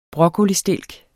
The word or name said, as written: broccolistilk